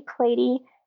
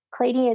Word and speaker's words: Klaty: It's just Ashley Klaty, and that's on YouTube, Instagram, everywhere. It's all the same. And Klaty is spelled K-L-A-T-Y. Looks like Klaty.